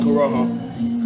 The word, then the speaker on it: Corojo: But how do you pronounce the Corojo? – Corojo. – Corojo.